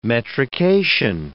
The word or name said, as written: metrication